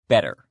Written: better

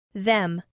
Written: them

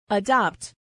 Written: adopt

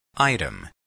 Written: item

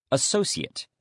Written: associate

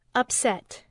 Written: upset

